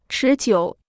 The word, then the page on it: 持久: chí jiǔ